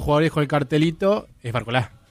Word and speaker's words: Barcola: ¿Por qué decís Barcola y no Barcola, Gus? – Sí, porque creo que la pronunciación es así, no lleva tilde, en este caso, sí, Mbappé, por ejemplo, y bueno.